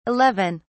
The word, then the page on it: 11: British English